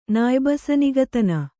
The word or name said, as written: ನಾಯ್ಬಸನಿಗತನ